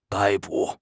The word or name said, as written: 逮捕